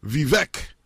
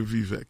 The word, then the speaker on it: Vivek: comment baka prononcer nom c'est Vivek en tout cas on m'a aidé me prononcer et Vivek.